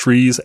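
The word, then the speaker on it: trie: So this is a tree that's spelled like tries and is technically pronounced trees, and nobody pronounces it that way.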